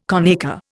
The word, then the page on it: conacre: Indian